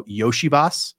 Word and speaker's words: Iosivas: which I've learned is now Yoshivas. You're supposed to pronounce his name.